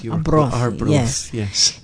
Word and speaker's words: Arbroath: Arbroath? – Arbroath. You were close. Arbroath, yes.